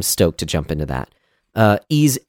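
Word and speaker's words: Ys: Y-S, apparently it's pronounced Ease.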